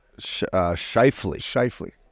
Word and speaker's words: Scheifele: Scheifele.